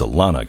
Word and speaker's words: Alana: I'm sorry, I've been saying Elena, but it's Alana Goodman.